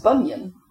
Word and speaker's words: Bunzion: So we've got the B-R-A-N-Z-I-E-T, pronounced Bring It, a farm and settlement near Bardowie in East Dunbartonshire. We've got B-U-N-Z-I-O-N, pronounced Bunyan.